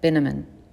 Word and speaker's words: Binneman: Then Grandma closed the front door and told me, Margaret, darling, this is Mr. Morris Binneman. Rhymes with cinnamon, he said to me.